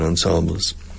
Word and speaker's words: ensembles: Conjuntos musicales, we say ensembles, but we Frenchify the pronunciation ensembles.